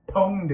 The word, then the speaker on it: pwned: Pwned is a derivative. It is spelled PWND, but it's not an acronym, as it turns out. It's just derivative from own.